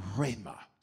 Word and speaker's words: rhema: Second Greek word is rhema. Say it with me. Rhema. Okay? Now, say it one more time. Rhema. And then say it like ma, like, you know, say, got it? So, rhema. You sound real spiritual now.